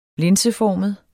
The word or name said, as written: linseformet